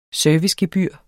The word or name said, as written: servicegebyr